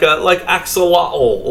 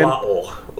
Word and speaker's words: axolotl: And I'm using like axolotl, like the Mexican indigenous language pronunciation. – You sounded like you came from like Edinburgh. Say axolotl. That again. – Axolotl.